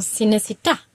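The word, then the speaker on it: Cinecittà: Die Betonung ist nochmal auf dem A. – Cinecita.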